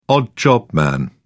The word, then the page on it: odd job man: British English